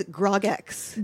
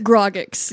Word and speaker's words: Grogx: A Gargbox? – I have it as Grogx. – Grogx. Yeah, because it's like Grogu X.